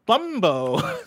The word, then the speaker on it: Bumbo: On The Legend of Boombo. – Boombo is spelled B-U-M-Bumbo. Bumbo. I like Bumbo. – The Legend of Bumbo. All right. – B-U-M-B-O.